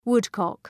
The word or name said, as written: woodcock